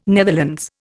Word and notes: Netherlands: Indian

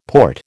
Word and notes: port: American English

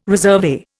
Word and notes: resurvey: Indian

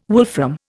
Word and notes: wolfram: Indian

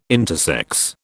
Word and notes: intersex: British English

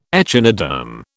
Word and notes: echinoderm: British English

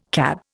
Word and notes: CAB: Indian

